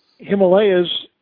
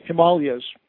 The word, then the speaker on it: Himalaya: Himalayas is how it usually gets written here, and in the UK you usually hear it pronounced Himalayas. So you could say all of the above, but being a purist, I always say Himalaya.